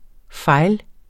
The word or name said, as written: fejl